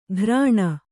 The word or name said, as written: ಘ್ರಾಣ